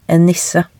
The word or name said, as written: en nisse